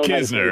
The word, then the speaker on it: Knizner: I think they have the opportunity with Knizner there. Is that how you say his name, by the way? I've never known Knizner.